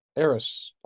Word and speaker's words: Eris: Eris. Am I saying that right? – Yeah. Yep.